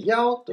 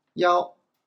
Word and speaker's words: 幺: 数字を 1 個ずつ読んでいくんですけど、例えば 1 はですね、やおと発音します。やお。